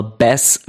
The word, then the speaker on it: Bass: Er wird wie Bass geschrieben, also wie der Bass, aber Bass wie der Barsch ausgesprochen.